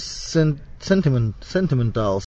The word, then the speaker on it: sentimental: And then the prosody, or the syllable stress on sentimental. Sentimental. Sentimental. Stresses on the next to the last syllable.